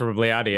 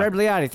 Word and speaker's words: Rebagliati: Riskalani, I can't even pronounce his name, from Canada when he got his gold stripped in 98. Like Ross Rebagliati, yeah. Rebagliati, thank you.